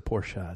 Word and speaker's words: Porsche: By the way, it's a Porsche. It's a two-syllable word.